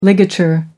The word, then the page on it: ligature: American English